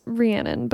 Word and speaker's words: Rhiannon: Yeah, so if you were ever wondering or if you ever wanted to describe to somebody how to say my name, it's Rhiannon.